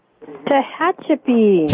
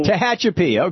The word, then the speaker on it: Tehachapi: Tehachapi. – Tehachapi.